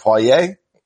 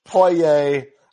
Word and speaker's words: Poirier: Is it Poirier? – Yeah, I think so. That sounds good.